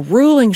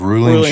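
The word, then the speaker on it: Reuling: Ruling Chardonnay, that is probably one of the finest Chardonnays in Sonoma.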